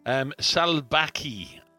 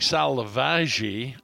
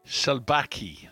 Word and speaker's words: Salvaje: Salbaki, I call it, that I pronounce it. It looks like Salvagi on the bottle, but it's actually Salbaki.